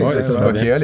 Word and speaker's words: Bokea: Euh, ce sont les fondateurs de Bokea, euh, qui se prononcent comme ça s'écrit. – Oui, Bokea, les gars.